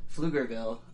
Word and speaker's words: Pflugerville: Pflugerville, Texas, which... – Pflugerville,